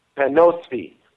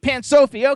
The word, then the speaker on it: Pansophy: Also, we want to thank Sabrina Coleman from Pansify PR. I don't know if – did I spell that? Did I pronounce Pansify? Is that how you pronounce it? – Panosphy. – Panosophy, okay. – Pansophy, okay.